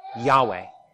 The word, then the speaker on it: Yahweh: The name of this God who has revealed himself to us is Yahweh. At least that is the pronunciation that we use, because we don't know the vowel pointings. They were lost to time. We know the Hebrew consonants.